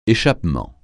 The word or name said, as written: échappement